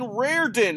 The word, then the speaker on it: Rairdin: And Jonathan Rairdin, am I pronouncing that right? – You are, but it's just John.